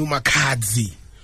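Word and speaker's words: Makhadzi: uMakhadzi umbuzo wodwa I pronounced that right yeah uyibize kahle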